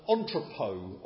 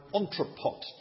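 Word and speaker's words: entrepôt: And it's called an entrepot, or an entrepot, depending on how you want to pronounce it.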